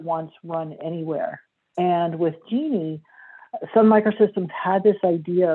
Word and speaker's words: Jini: It's spelled J-I-N-I, but pronounced like the woman's name, Genie.